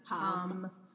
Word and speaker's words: Ham: Spelled like ham. – Yeah, but pronounced hum.